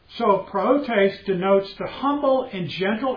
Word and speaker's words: prautes: The word for meekness is Prautes, and it's spelled P-R-A-U-T-E-S, P-R-A-U-T-E-S, pronounced Prautes.